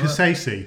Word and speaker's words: Kasese: Is it Kaisess or something? – Oh, Kasese. – Like Kasese. – Yeah, yeah. – Explain a little bit about what happened here. – So Kasese is a beautiful place, actually.